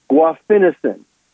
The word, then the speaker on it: guaifenesin: It's called guaifenesin. G-U-A-I, F as in Frank, E-N-E-S-I-N. Guaifenesin.